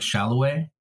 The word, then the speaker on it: Saloy: So who we said Saloy is pronounced more like Shalloway.